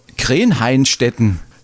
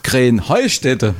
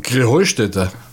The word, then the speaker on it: Krähenheustetten: Ja, ihr saget immer Krähenhainstetten und dabei heißt es doch Krähenheustetten, oder wie ist es? – Krähenheustetten. – Ja, genau, und das ist die Betonung auf dem Heu.